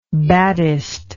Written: baddest

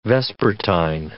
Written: vespertine

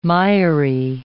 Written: miry

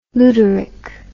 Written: luderick

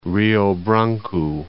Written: Branco, Rio

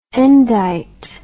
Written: endite

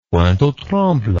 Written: pointe-aux-trembles

